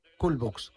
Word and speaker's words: Cool Books: Cool Books. – Cool Box. – Se escribe C-O-O-L. – Ah, cool, frío. Cool.